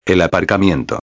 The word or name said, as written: el aparcamiento